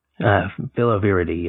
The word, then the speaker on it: Filoviridae: But they both come from the same family of viruses, filovirinae. How do you say it? – Filoviridae, yeah. – Yeah, that's how you say it.